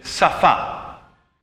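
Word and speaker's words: Safa (שפה): La E, che sarebbe una H muta, praticamente funziona da accento. Perciò si va a leggere Safa.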